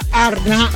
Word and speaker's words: Arnad: Si pronuncia Arna. Ciao. – Ciao. – Sono Però sei di Busto Arsizio. Ci vorrebbe uno della Valle d'Aosta, tipo Andrea. – Si pronuncia Arna senza la D.